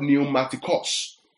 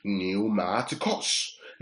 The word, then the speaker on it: pneumatikos: You see, the word there, spiritual, is the word pneumaticos. Now, pneumaticos, I'll spell that for you. P-N-E-U-M-A-T-I-K-O-S. Pneumaticos.